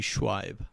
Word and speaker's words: Shuaib: You did not, but it's not an uncommon issue. – Yeah. – It's actually Shuaib.